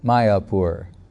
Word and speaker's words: Mayapur: But when Bhaktivinoda Thakur was searching on the British made maps of everything, and there was some place, and it was called, the way they spell it was M-Y-A-P-U-R, like Mayapur, Mayapur.